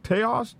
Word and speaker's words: Taos: Is this Taos? Taos News tells us that multiple shootouts, and this has maybe been updated a little bit. – It's pronounced Taos. – Taos. Thank you. – Yeah, like mouse.